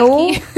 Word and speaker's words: Walkie: Walkie. Es que, a ver, no, corríjanme. ¿Cómo se dice? Se escribe W-A-L-K-I-E.